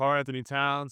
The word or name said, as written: Karl-Anthony Towns